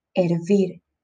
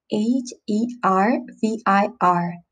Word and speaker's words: hervir: H E R V I R, hervir. If you notice, uh, I don't pronounce the H at the beginning. It might be because I'm from Latin America, so I don't say hervir, I say hervir. The H is mute. So it's not hervir or hervir, no, it's hervir.